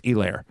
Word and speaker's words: Helaire: And I'm the one, and I would, when I first got on the air, I said Helaire as well. I'm just amazed that no one at Fox got in his ear and said, like, by the way, it's Helaire. Because Aikman said it right.